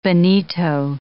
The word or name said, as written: bonito